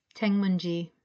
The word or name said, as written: Tengmunji